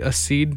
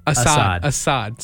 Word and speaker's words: Assad: But it's Chris Tomlin featuring Audrey Assad. Did I say that right? – Nope. – Assad.